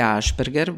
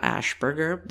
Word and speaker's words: Ashperger: I don't want to be Cynthia Ashperger, which is my pronunciation of my name in Croatian. I don't want to be Cynthia Ashperger. This is my pronunciation of my name in English.